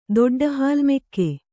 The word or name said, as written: ದೊಡ್ಡಹಾಲ್ಮೆಕ್ಕೆ